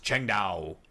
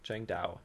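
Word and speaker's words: Tsingtao: Also, I believe it was Kevin who mentioned he likes the Asian beer, Sing Tao. It is actually pronounced Cheng Dao. – Did he spell it out phonetically? – Yeah, Cheng Dao. – Chang Dao.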